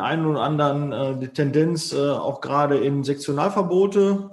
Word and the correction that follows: Sektoralverbote: sagt man das Sektionalverbote, äh, Sektor, Sektoralverbote.